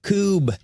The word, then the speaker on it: Koob: Dude, it's Dr. George Koob. – Okay, that's what it is. – K-O-O-B. Yep. It rhymes with boob? – Yeah.